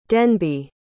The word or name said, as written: denby